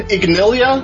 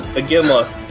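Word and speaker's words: Iginla: Jerome Ignilia. – Aginla.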